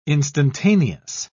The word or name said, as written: instantaneous